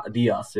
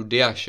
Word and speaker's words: Dias: že třeba polovina lidí Diašovi prostě říká Dias, jo, jak nějaký Mexikánec by byl, nebo špatně. – Já, já reálně ani nevím, jestli to je opravdu Diaš, jo, je, takže jako, je, je, je.